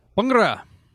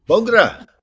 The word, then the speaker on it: Bhangra: And how do you say it? Bhangra? – Bhangra. – Bhangra. You got to say Bhangra. – Bhangra. Yeah, I mutilated. I was not born speaking Punjabi. I didn't, you know, start encountering Punjabi words until I was almost 30. So, you know, I'm still doing my best. Aspirated syllables can be very tricky.